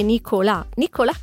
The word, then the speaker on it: Nicolas: ma lui dice: mio padre me l'aveva dato per pronunciarlo alla francese, ma in quel caso senza la H sarebbe Nicolas Cage, che non sarebbe male.